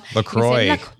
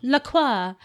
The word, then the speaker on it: LaCroix: LaCroix. – LaCroix. Or you can LaCroix. – Yeah. That's the US. That's the US pronunciation.